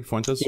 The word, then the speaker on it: Fuentes: Fuentes? – Yes, correct, correct.